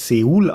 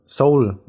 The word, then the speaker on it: Seoul: Da erst habe ich gelernt, nach 37 Lebensjahren, dass man die südkoreanische Hauptstadt ja gar nicht Seoul ausspricht, sondern Seoul, wie die Musik. Wer soll denn sowas wissen? Das macht überhaupt keinen Sinn. Geschrieben wird hier Seoul, deswegen sage ich auch weiterhin so dazu.